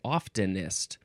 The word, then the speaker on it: oftenest: Also, of some adjectives and adverbs of two syllables, as in luckiest and oftenest. Or some people might say oftenest. They like to pronounce the T.